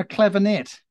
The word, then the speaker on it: clavinet: But one of my favorite intros for any song, played on a clavinet.